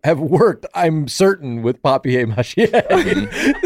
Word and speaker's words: papier-mâché: No way has anyone ever pronounced it like. – It's French. It's like a French word. – Yeah. – Papier-mâché. – Paper mâché. Sorry, I'll pronounce it your way. – Let me get it down to Sarah Squirm style. – Paper mâché. – It's papier-mâché. – It's papier-mâché.